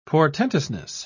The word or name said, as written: portentousness